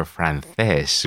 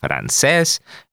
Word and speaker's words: francés: Okay, so we can just point out here the difference in Latin American pronunciation would be francés, having the same pronunciation with letters C and S, francés, and the Spanish pronounce it francés.